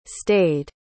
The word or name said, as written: stayed